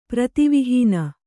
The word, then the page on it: ಪ್ರತಿವಿಹೀನ: Kannada